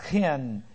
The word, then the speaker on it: chen: By the way, his name will be changed in verse 14 to, it's translated hen here, but it's chen, C-H-E-N. It's a hard C-H sound, and it means grace.